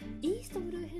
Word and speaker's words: 東の海: をしていきたいと思う時間がないでは「ワンピース最初旅立っていったルフィが主人公のルフィが旅立っていったイーストブルー編ですねこれ 1 巻から、えーと、 12 巻までの間東の海と書いている、えー、東の海と書いてイーストブルー